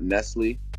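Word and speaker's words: Nestlé: In round 11, we got Rolex, we got Sony, and we got Nestle. I said that right? – Yep. – Cool.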